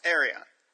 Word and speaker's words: Arion: I say Orion. You say Arion? – Arion. – Okay. You're probably right.